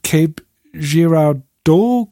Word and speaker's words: Cape Girardeau: Crash Retrieval in Cape Girardeau, glaube ich, spricht man das aus, genau.